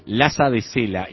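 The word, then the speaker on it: Lhasa de Sela: Laza Lo voy a decir como suena así. – De. – Laza de Sela. Y Laza se escribe L-H-A-S-A. De sale.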